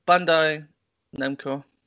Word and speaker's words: Bandai Namco: So, so Bandai Namco,